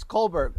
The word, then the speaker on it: Colbert: is it Colbert or Colbert? Which one is it? You can pronounce it correctly. It's Colbert, Chris Colbert.